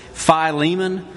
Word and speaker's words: Philemon: Now, I have to confess, for most of my life, I've pronounced this Philemon, which may be a little bit overemphasis on the first syllable the way we do in South Alabama sometimes. So I'll try to say Philemon all the way through.